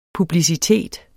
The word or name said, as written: PUBLICITET